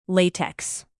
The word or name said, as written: latex